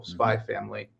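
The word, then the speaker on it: Spy x Family: But like every anime fan that I know has been like, you have to watch this show, Spy Family. There's an X in the title, but I don't think you're supposed to pronounce it.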